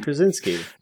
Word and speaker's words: Krasinski: Krasinski.